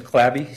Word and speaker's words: Clabby: First name is Corey, C-O-R-E-Y. Last name is Clabby, C-L-A.